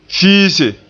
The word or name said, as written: fiise